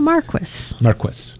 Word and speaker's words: Marquis: Oh, I'm sorry. Marquis. – No, Marquis. – Marquis. Get the accent on the right syllable.